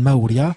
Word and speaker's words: Maurya: Et sur leur environnement, et elle va être instruite donc par ce maître Maurya, qui se prononce en inde Maurya,